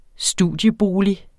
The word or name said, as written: studiebolig